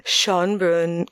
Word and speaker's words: Schönbrunn: Franz Joseph I was born on August 18th, 1830 in Schönbrunn Castle. I know that's pretty close to you, Johanna. Did I pronounce that right? – Yeah, it's you pronounce it right.